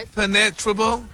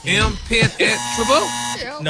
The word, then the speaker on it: impenetrable: Impenetrable. Uh-huh. Impenetrable. – No, your emphasis is on the wrong syllable.